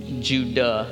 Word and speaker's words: Judah: This would actually really be Judah, is the way his name is actually pronounced, but...